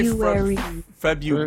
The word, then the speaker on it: February: How you say February? But that's not as Ruary. February. – You're not pronouncing it. See, in Spanish, you do pronounce the R. So that means in English, you have to pronounce the R as well.